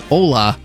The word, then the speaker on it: hola: No, it's hola. – Oh, it is hola? – Did you just say hola? – Cuz it's hola. – O-L-A. Hola. – It's like hola but with a silent h.